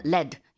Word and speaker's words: lead: LEAD인데 리드라고 읽지 않고요. 납일 때는 LED 이렇게 발음하거든요.